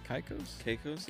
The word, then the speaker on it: Caicos: Caicos? – Yeah.